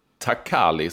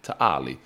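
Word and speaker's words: Ta' Qali: De har ju dock en arena där, Takali som vi säger, men man ska inte uttala Q-et, Taali.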